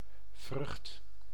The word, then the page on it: vrucht: [vɾyχt]